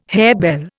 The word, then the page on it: harebell: Indian